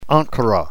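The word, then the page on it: ankura: New Testament Greek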